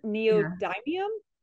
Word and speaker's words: neodymium: I got dysprosium, neodymium.